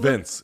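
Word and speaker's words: Vinc: Vink Pichel. Is that how I'm saying it correctly? – Vince. He doesn't spell it with an E, but it's Vince.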